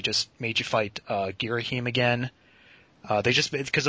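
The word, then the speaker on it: Ghirahim: That, uh, that gives me a nice segue into talking about the controls because the battles with Girahim is that how you say his name? – It's H-I-M. I think it's Girahim.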